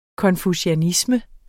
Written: konfucianisme

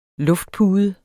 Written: luftpude

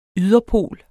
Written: yderpol

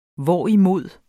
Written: hvorimod

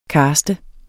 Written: CASTEDE

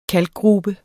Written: KALKGRUBER

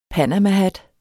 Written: panamahat

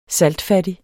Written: saltfattig

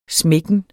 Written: smækken

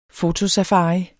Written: FOTOSAFARI

